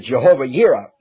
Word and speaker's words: Jehovah Jireh: But now all of those are found in various scriptures, such as Jehovah Jireh. We pronounce it Jehovah Yireh, what it really is pronounced.